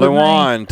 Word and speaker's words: Lewan: Lewan, Taylor Lewan.